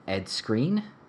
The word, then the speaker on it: Skrein: Ed Skrein.